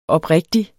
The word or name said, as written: oprigtig